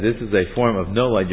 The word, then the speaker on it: nolad: And reason B of this is it's a form of, the word is nolad. In English it's spelled N-O-L-A-D. N-O-L-A-D.